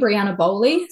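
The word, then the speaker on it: Briana Bowley: So all of my social media is under my name, Brianna Bowley. So that's spelled B-R-I-A-N-A and Bowley, B-O-W-L-E-Y.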